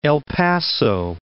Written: El Paso

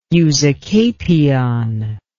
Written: usucapion

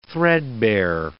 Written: threadbare

